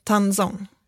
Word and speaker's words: tangzhong: Har du hört talas om, um, om tangzong? Nej. Har du inte det? Kanske är det ditt uttal som gör att det känns som att jag T-A-N-G-Z-H-O-N-G. Tanzong.